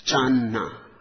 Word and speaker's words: Channa: When it reached China, the Chinese masters, Hui Neng and others, pronounced it as Channa.